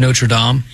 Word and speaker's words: Notre Dame: I believe it's pronounced Notre Dame.